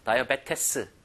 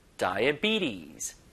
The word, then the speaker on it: diabetes: 자, 스펠링과 실제 읽는 게 너무 달라요. 다이어베테스. 이게 아니라, 다이비티스.